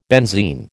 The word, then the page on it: benzene: American English